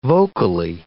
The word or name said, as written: vocally